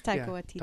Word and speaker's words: Taika Waititi: Taika Waititi. – I just can't get this stuff up quick enough sometimes. – It's Taika Waititi.